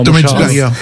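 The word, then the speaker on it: Thomas Hitzlsperger: Il y a Thomas Hilfiger-Gerber, peut-être que tu as mieux le prononcé que moi. – Thomas Hilfiger. – Voilà, parfait.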